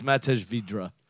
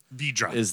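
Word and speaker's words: Vydra: And it's Matej Vidra. – Vidra.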